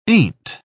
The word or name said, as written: ain't